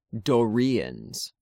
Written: Dorians

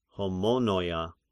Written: Homonoia

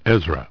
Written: Ezra

